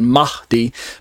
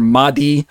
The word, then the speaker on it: Mahdi: So this next short paragraph here is entitled The Mahdi and the Antichrist. M-A-H-D-I. I'm saying Mahdi. D, where there's kind of a breathy sound on the H part, Mahdi.